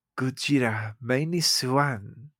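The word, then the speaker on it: Gojira Minus One: But speaking of that, I did see another Japanese movie, Gojira Mini Swan. That's how they say it on the ads.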